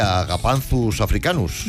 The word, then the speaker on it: Agapanthus africanus: Porque es mucho mejor llamar Josefina o Pepita una planta que Agapanthus africanus.